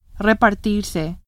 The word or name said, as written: repartirse